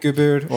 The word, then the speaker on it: Gübür: Koray Tuğberk Gübür or Koray, Koray, Koray.